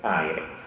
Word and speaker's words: Aire: Ed è, qui rischio un errore di pronuncia, si dice Aire o Aire? – Aire.